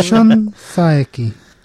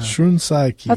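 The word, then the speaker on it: Shun Saeki: Is that pronounced right? You're our expert. – Why is he our expert? – Shun Saki, as far as I can tell. – Shun Saki.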